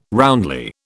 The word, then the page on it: roundly: British English